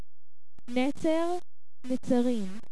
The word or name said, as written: Netzarim